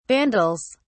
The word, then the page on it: vandals: British English